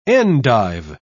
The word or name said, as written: endive